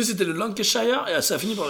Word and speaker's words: Lancashire: Lancashire.